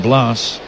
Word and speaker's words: Blas: He spells it B-L-A-S. Take a few guesses, Ron. How would you pronounce B-L-A-S? – George.